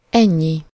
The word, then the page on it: ennyi: /ˈɛɲːi/